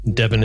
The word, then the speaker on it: Devon: I do write about mostly Canadian politics, but also some British, New Zealand, and Australian politics at Devin Rowcliffe. So Rowcliffe is R-O-W-C-L-I-F-F-E. Devin is spelt like the county.